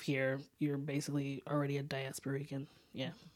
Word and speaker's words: Diasporican: So begin by telling us, what is a diaspora, a diasporic? I said it the wrong syllable again. Diasporican.